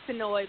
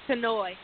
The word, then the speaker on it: Tinoi: Hey, yes, it's Tinoi, but yes, I'm here. – Hey, I hope I'm pronouncing your name correct. – Tinoi, okay, my bad.